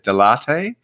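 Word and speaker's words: Dell'Arte: If I got this right, sounds like a coffee, but Delarte? – Yeah. – Would that be correct? Is that the right way to say it? – Yeah.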